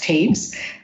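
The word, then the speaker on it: Toews: I'm going to spell her last name for you in case you want to look for this book, because it's T-O-E-W-S, which apparently is pronounced Taves.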